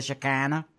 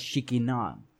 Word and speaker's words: Shekinah: I know in the South here they say the Shekinah, but it's actually pronounced Shekinah.